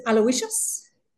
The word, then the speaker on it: Aloysius: Let me pronounce it, Aloysius.